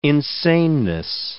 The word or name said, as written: insaneness